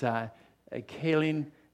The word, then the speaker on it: Kylynn: It's hard to pronounce. K-Y-L-Y-N-N. You say Kaylin, whatever you want to pronounce it.